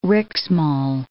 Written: Riksmaal

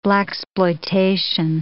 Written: blaxploitation

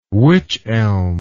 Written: wych elm

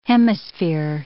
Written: hemisphere

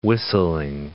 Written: whistling

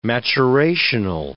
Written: maturational

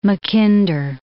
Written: Mackinder

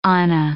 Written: anna

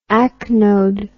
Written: acnodal

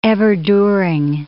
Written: everduring

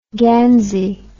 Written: gansey

